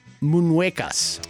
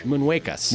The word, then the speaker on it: Muñecas: La Isla de las Muñecas. – I got a D in Spanish. – Muñecas. – Muñecas.